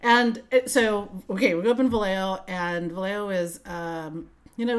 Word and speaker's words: Vallejo: Do you pronounce the L's? – Vallejo. It's like, let me think about that. Yes, I do.